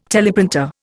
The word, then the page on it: teleprinter: Indian